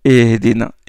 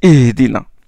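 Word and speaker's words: ihdina (اهدنا): Ihdina, ihdina. Ça, c'est le ha. Ihdina. Pour le ha, éviter l'aspiration très légère. Et il y a des frères qui disent ihdina, ihdina. Non, c'est ihdina.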